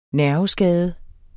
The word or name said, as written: nerveskade